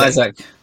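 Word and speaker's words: Isak: How do you pronounce Isaac. Isaac.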